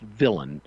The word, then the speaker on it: villein: It's pronounced villain. It's spelled differently from its pronunciation. It's spelled villein, V-I-L-L-E-I-N.